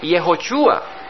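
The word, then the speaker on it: Yehoshua (יהושע): El nombre Josué es de descendencia hebrea y en el verdadero hebreo se pronuncia Yehoshua y en inglés es Yahshua, Yahshua, Yahshua, Joshua.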